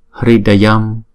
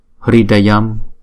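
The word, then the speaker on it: hṛdayam: Chữ này chúng ta đọc là, chữ R có dấu nặng này chúng ta đọc là Ri. Và có một số vùng miền ở Ấn Độ họ đọc là Ru. Và cũng có một số vùng miền khác họ đọc là R, R, R, không? Thì chúng ta đọc là, chữ này chúng ta đọc là Ri đi, thì chúng ta đọc là Hridayam, Hridayam.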